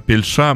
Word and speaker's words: Пельш: В 96 году э, Пельша, ну так, наверное, его правильно э, именовать, с ударением на последний э, слог, дебютирует в мюзик мюзикле, сыграв роль Пикассо э, в «Жизнь в голубом» Роберта Осейна.